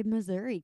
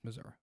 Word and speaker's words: Missouri: Why do people from Missouri pronounce their own state wrong? – We live here. We pronounce it right. You're pronouncing it wrong. Well, I was born here. It's Missouri. – No, thanks. – It's literally not, though. – But it is. – There's no A in it anywhere.